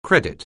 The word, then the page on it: credit: anglais